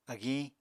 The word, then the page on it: agiñ: Apatani